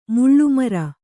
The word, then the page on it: ಮಳೆಮರ: maḷe mara